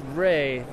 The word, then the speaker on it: Rhea: Am I saying that correctly? – That's Ray, but Ray. – Great first time. – R-H-E-A, pronounced Ray.